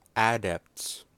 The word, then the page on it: adepts: American English